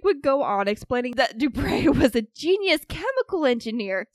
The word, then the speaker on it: Dubray: The pronunciation in the notes was Dubray. Like a donkey bray.